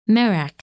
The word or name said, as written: Merak